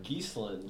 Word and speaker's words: Geeslin: Geeslin, just like the bird, plural.